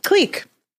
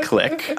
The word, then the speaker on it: clique: So, on the Jawbreaker episode, which will drop at some point in the near future, in Canada, we pronounce it clique, not click.